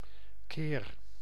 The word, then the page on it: times: /ˈtaɪmz/